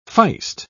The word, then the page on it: feist: British English